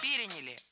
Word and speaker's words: переняли: Мы успешно эту традицию переняли, и Новый год превратился в главное зимнее светское событие. – Переняли. И это тоже ошибка. Правильный вариант – переняли. Ударение ставим на третий слог.